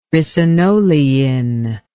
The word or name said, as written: ricinolein